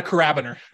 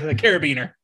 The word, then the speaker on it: Carabiner: Yeah, it's not a Carabiner. It's a Carabiner.